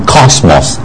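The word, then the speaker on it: Cosmos (κόσμος): 是 Sophie 啊，我们主要是学这个 Yota 的发音啊，Sophia F 加上 Yota Sophia Fia Fia Sophia，然后下面一个字在第三个 column 哈、啊、最右边啊，在那个 King 旁边，你看到 Cosmos 世界，就是在类似 K 的发音 Cosmos 啊。那 lambda 就是世界，神爱世人，其实原文是神爱世界，啊，所以其实神不是那么直接讲的，神爱世界每一个人，是神爱世界属他的人，原文是 cosmos，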